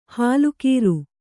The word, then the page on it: ಹಾಲುಖೀರು: hālu khīru